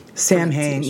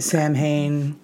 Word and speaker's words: Samhain: Halloween falls on October 31st because the ancient Gaelic festival of Samhain, which is spelled S-A-M-H-A-I-N, very different. – Samhain, yeah. Like, I've been saying it Samhain forever. – Me too. So Samhain.